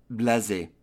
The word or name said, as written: blase